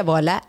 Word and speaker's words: Voila: Voila, así como se pronuncia, voila, RD.